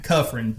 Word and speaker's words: Kufrin: Kufrin.